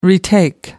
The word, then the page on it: retake: American English